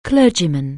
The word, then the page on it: clergyman: anglais